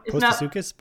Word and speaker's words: Postosuchus: Sucus? Is it Sucus? – That's how I've been pronouncing it. Postosuchus. – Postosuchus. – Postosuchus. – It's like a pistachio.